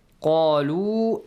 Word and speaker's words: قالوا: Then, قَالُوا آمَنَّا. قالوا. قَا has a light sound or heavy sound? – Heavy sound.